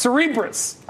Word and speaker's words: Cerebras: For more on Cerebras, Oliver, apparently I've been mispronouncing the company's name from day one, so you forgive me for just wanting to highlight that it's pronounced Cerebras.